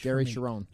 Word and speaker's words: Cherone: No, you know how I can never pronounce the guy's the lead singer from Extreme, another guy, vocal range. – Gary Cherone. – Cherone. I always say, yeah, I don't pronounce it right.